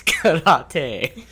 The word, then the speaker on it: karate: First of all, it's karate.